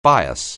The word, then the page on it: bias: anglais